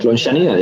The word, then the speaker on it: Lonxania: Why don't we listen to track 5, Lonchania. Did I pronounce that correctly? – Perfect, Lonchania, yeah.